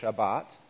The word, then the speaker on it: Shabbat: The Hebrew word, I think if we were pronouncing it Hebraically, we would say Shabbat.